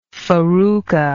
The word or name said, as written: farruca